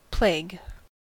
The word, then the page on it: plague: American English